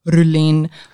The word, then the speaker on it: Roelene: I hate my name, Roelene. It's actually pronounced Roelene, but again, everyone like, yeah, it's Roelene. They're like, what? Rowena? Raylene? – Roelene?